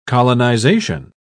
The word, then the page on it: colonisation: British English